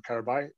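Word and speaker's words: Qarabağ: and Karabay from Azerbaijan. I've been learning Turkish, and uh, I'm going to stick with that pronunciation of Karabay.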